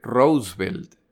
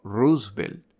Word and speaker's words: Roosevelt: En 1940, cuando los alemanes dejan claro que planean invadir Gran Bretaña, el presidente Roosevelt, a propósito, ya me corrigieron la pronunciación de este nombre. Se debería decir Roosevelt, no Roosevelt pero el nombre de este señor ha sido maltratado demasiadas veces hasta este punto para cambiar.